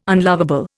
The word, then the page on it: unlovable: Indian